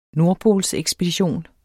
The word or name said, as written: NORDPOLSEKSPEDITION